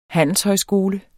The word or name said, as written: handelshøjskole